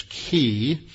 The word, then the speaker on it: chi: Another way they would do that is what was called a chiasm, C-H-I-A-S-M, from the Greek letter chi, or pronounced mostly in Greek as key.